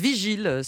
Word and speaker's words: Vigil: Si on prononce à l'anglaise Vigil, sans le dieu.